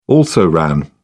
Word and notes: also ran: British English

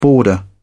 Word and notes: boarder: British English

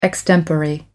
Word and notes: extempore: American English

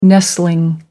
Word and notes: nestling: American English

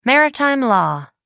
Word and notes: maritime law: American English

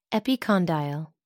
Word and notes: epicondyle: American English

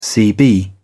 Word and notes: cb: British English

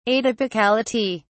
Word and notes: atypicality: British English

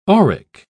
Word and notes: auric: British English

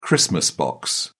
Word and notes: christmas box: British English

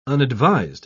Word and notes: unadvised: British English

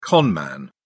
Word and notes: con man: British English

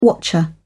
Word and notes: watcher: British English

